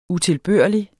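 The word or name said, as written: UTILBØRLIGT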